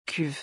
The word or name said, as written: cuve